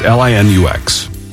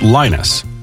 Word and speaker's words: Linux: And some people insist, nope, it's pronounced Linus. And I'm like, okay, but there's an X. And so we tend to pronounce the X, you know, with a X sound on it.